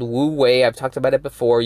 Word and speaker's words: Wu Wei: It's Wu Wei. It's spelled W-U space W-E-I. Wu Wei.